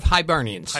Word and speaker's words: Hibernians: Ancient Order of Hibernians.